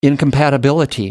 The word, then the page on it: incompatibility: American English